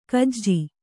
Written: ಗೂಜು

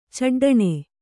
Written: ಚಡ್ಡಣೆ

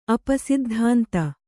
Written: ಅಪಸಿದ್ಧಾಂತ

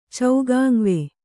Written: ಚೌಗಾಂವೆ

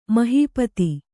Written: ಮಹೀಪತಿ

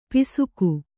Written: ಪಾಸಿಕೆ

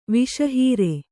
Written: ವಸುಹರ

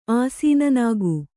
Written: ಆಸೀನನಾಗು

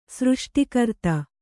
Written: ಸೃಷ್ಟಿಕರ್ತ

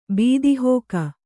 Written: ಬೀದಿಹೋಕ

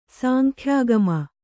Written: ಸಾಂಖ್ಯಾಗಮ

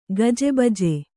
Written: ಗಜಬಜೆ

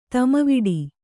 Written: ತೊಮ್ಮೆವಡೆ

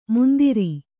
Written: ಮುಂದಿರಿ

